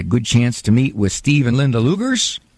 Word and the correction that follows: Leagers: Oh, Leagers. That's the way to pronounce it. Sorry about that. Pronounce Leagers.